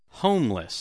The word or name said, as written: homeless